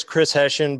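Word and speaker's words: Chris Heshin: And my name on there is Chris Hessian, but it's spelled differently, H-E-S-H-I-N, more like what it sounds like.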